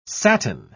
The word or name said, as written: satin